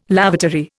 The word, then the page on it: lavatory: Indian